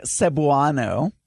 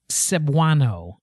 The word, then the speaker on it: Cebuano: He's saying Cebuano. – Cebuano.